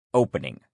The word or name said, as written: opening